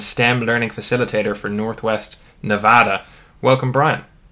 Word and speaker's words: Nevada: Well, Nevada is, and I think that happens because it's really a Spanish name. It means snow-covered mountains, and so if you were pronouncing it in Spanish, you'd probably say Nevada. But I think the early settlers here that were miners and ranchers and whatever probably changed the pronunciation of the word.